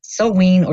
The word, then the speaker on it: Samhain: So, Halloween traditions in the West date back thousands of years to the festival of Samhain, pronounced mm-hmm. Samhain, sauin, or Samhain, and it is the Celtic New Year festival.